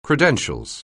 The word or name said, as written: credentials